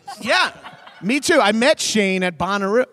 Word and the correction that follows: Bonnaroo: Why am I pronouncing it like that? I'm French. – Bonnaroo.